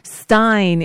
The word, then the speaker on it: Rosenstein: the news broke that Rod Rosenstein, Stein, Joe Scarborough, Stein, it's pronounced, whoever I heard say it again today wrong,